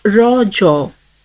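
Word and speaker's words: Raja: The festival is natively pronounced as Raw Jaw, a local term that means menstruating woman.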